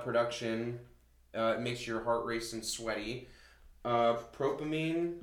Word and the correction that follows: dopamine: Oh, dopamine.